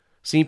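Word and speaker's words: Cine: Seen projects as a start, and again, it's C-I-N-E, but it's pronounced Seen, like as in homophone style, S-E-E-N.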